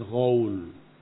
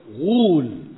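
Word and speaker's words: ghul (غول): Sering keliru saya membacanya dengan ghaul, padahal bukan ghaul. Kata beliau ghul dengan dhamma.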